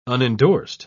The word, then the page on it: unendorsed: British English